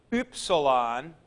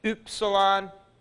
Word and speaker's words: upsilon: We get it in words like hym, H-Y-M-N. And that upsilon is pronounced with that umlaut, U, U. It's upsilon, U. Now you can do this best by making your mouth as if you're going to say ooh, and then with your mouth in that shape, you say e.